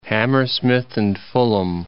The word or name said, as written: Hammersmith and Fulham